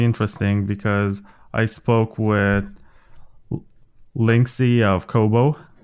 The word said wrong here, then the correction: Lixin: I hope I didn't mispronounce his name. Lixin. I said Linksy. Lixin.